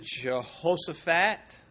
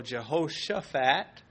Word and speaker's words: Jehoshaphat: Now, you can pronounce it Jehoshaphat or Jehoshaphat. Both work.